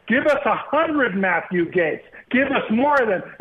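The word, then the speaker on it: Gaetz: And I'm supposed to get upset about this Mike Getz? How do you pronounce it? Gates.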